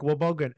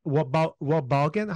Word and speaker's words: Wobegon: So obviously you are the founder of the, essentially the Lake Wobegon. Wobegon? How do you pronounce it? – Wobegon. – Wobegon. Wow. I was off.